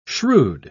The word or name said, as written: shrewd